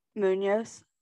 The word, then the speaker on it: Munoz: Munoz.